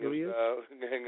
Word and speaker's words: Gangnam Style: Because, number one, what's that, Gangnam Style? How do you say that? Is that who he is? – Gangnam Style.